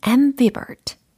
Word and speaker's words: ambivert: A, M, B, I, V, E, R, T.